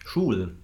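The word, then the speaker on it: Žulj: Ähm, ist vielleicht auch ganz interessant zu erfahren von dir, wie du glaubst, wie, wie der Abgang von Robert Sui, kannst du kurz den Namen aussprechen? – Schul. Genau. – Sorry? Schul. – Einfach wie die Schule, Schul.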